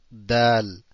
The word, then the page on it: د: Dal